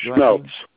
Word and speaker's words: Schmelz: Schmelz. – Schmelz. S-M-E-L-T-Z? – Yes. – S-C-H-M-E-L-Z.